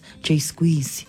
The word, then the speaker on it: JSQZE: Now, I've got to admit that when I first saw the name of that particular artist, I thought their name was J-S-Q-Z-E, but then Finger corrected me. It's like, hey, it's pronounced Jay Squeezy or Jay Squeezy.